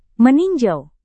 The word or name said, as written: meninjau